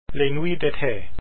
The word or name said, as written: Les nuits d'été